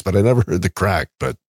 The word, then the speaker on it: craic: The first phrase is, what's the Craix? – C-R-A-I-C. See, now you've just outed yourself as not really Irish. – Oh, really? – because that is crack. That is how they spell it in Ireland.